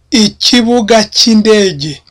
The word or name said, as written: ikibuga